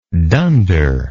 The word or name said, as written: dunder